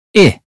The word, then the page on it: ship: British English